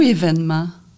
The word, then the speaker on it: événement: Le mot événement, lui, il pose encore problème. Et dans la presse, on a adopté l'accent grave. Alors, on l'écrit comme on le prononce.